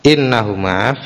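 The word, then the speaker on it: innahuma (إنهما): Qalal Istakhri warrawyani min a'immatina kamalikin wa ahmad innahuma. Ini biasanya kalau makul qaul hamzahnya di kasroh ya, hamzahnya di kasroh, jadi bacanya innahuma, karena isi ucapan dari qala innahuma tahirani minal makul.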